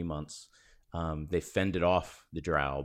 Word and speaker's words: drow: So he's a mountain dwarf, hill dwarf, and you know, they were attacked by random sort of drow or drow. How do you say it? – Drow. I would have said drow. – I say drow. – You both say drow.